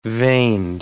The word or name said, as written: veined